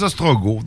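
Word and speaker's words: Ostrogoth: C'est des Ostrogos. Donc, on prononce pas le T.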